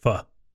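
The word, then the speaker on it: phở: Faux. Which I think that is actually pronounced pho.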